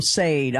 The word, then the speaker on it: Sade: Or that's a singer, yeah, Sade, man. Yeah, exactly. Yeah, I said it wrong on live on radio. I called him Sade. I'm like, oh, I never lived that one down. So, yeah, Sade.